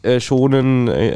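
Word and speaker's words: Shōnen: Shonen, ist das richtig ausgesprochen? Keine Shonen.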